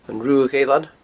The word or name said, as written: Ruadh Eilean